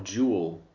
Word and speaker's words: jewel: There is kind of like a second syllable there, but it's like a half a syllable. Jewel. Ooh. You know what I mean? There's two different sounds there.